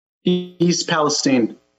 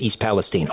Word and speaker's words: East Palestine: Now, first of all, is it pronounced East Palestine or Palestine? I've heard both. – East Palestine. – It is East Palestine. Okay, thank you for that.